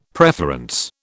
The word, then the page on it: preference: British English